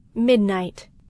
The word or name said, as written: midnight